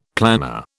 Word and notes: planar: British English

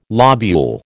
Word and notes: lobule: American English

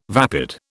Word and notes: vapid: British English

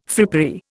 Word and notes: frippery: Indian

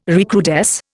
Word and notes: recrudesce: Indian

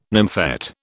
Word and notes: nymphet: British English

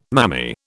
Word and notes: mammy: British English

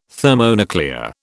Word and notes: thermonuclear: British English

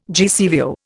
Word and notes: GCVO: Indian

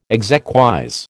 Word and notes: exequies: American English

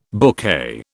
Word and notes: bouquet: British English

